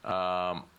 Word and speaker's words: Iizuka: There's a pre-tape promo with the Steiners as they discuss facing Fujinami and Iizuka, or Iizuka. I don't even know how you pronounce that. – Iizuka.